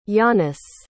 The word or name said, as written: jannis